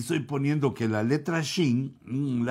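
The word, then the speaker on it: shin (ש): se pronuncia con los hebreos, más o menos lo que entiendo, se pronuncia como SH, como shin,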